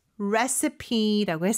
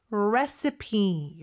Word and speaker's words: recipe: 자, 레시피, recipe 이렇게 발음하고요. 근데 스펠링은 R-E-C-I-P-E예요. – Yes, I think the first time I saw this word, I thought it was recipe. – 맞아요. 그렇게 마치 읽어야 될 것처럼 보이지만 recipe라고 읽습니다.